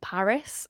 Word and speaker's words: PVRIS: So I love a band called Paris. It's spelled P-V-R-I-S.